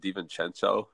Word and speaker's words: DiVincenzo: And I think, too, what's it, DiVincenzo? Is that how you pronounce it? I always mess up his name. – Yep.